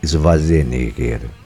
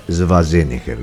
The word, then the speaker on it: Schwarzenegger: Bueno, hoy cumple 79 años el señor Arnold Schwarzenegger, o Schwarzenegger, porque es austríaco, alemán, así que la pronunciación sería Schwarzenegger. Lo que pasa que en Estados Unidos no sirve ese nombre.